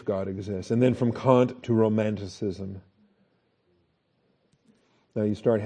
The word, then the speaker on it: Kant: Immanuel Kant, or Kant, I think Kant is the appropriate pronunciation, but I don't care. Kant, can't, you know.